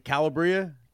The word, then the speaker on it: Calabria: So Fresh Prince, number four is, I don't know how to say it correctly, Calabria? – Yeah, Calabria.